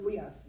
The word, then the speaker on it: huios: It's spelled H-U-I-O-S, the full-grown son, the pattern son, and it's only on paperback. So it's the Weos, right? – The Weos, yeah.